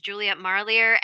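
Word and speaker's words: Juliet Marillier: There's also an author named Juliet Marlier, and I hope I'm pronouncing her name right, because I've mentioned her in a few interviews, but I think it's correct. I may not be correct. – It sounds right.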